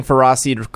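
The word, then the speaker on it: Ferroseed: Yes, I'm saying Ferrosseed correctly. You are all saying it wrong.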